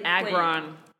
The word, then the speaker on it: Agron: And so the person that I would cast Lauren as in a drama is Diana, I think you pronounce it Agron, who played Agron.